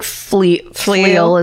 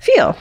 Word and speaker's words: Pfeil: So in 1964, Helen Fleil, I don't know how to, P-F-E-I-L, spell it like you say it. – Yeah, that looks, I think Fleil is good. – Fleil.